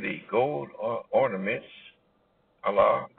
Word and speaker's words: Allah: Allah, speaks to us in the book known as the Holy Quran.